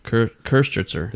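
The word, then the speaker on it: Köstritzer: This is the Kostritzer. I think I'm saying that right. – Mm-mm. – You have the umlauts of the O. – Kostritzer. – There you go.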